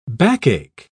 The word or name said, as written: backache